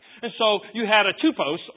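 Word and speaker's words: typos (τύπος): They pronounce it different, but it's the same word, typos. T-Y-P-O-S, typos.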